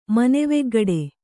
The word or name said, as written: ಮನೆವೆಗ್ಗಡೆ